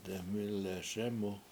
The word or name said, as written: deMIle7 SEmu7